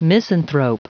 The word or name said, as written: misanthrope